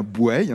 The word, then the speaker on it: Bouaye: 8500 habitants à Bueil, et on dit bien Bueil, hein, même si ça s'écrit B-O-U-A-Y-E, on dit en général Bueil.